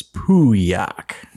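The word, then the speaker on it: Pauillac: The Legend Pouliac 2015. – Hey, not too bad. You don't pronounce the L's, though. It's Pouliac.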